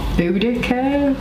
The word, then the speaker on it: Boudicca: and I could memorize all the monarchs from Boudicca.